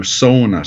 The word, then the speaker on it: sauna: You know, in northern Minnesota, where there's a lot of Finlanders, you know, by heritage, they're very proud of the word sauna, S-A-U-N-A, and it's pronounced so, like S-O-W, sauna, sauna, you know, so it's an elongated vowel on the front end,